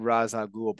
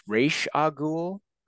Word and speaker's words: Ra's al Ghul: I've always said Ra's al Ghul, but it's Ra's al Ghul. – Like I said, it depends if you're doing the Arabic pronunciation or you're doing the English pronunciation.